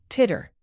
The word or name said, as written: titter